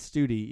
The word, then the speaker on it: Stute: His name is actually pronounced Miles Studi, even though it's spelled S-T-U-T-E.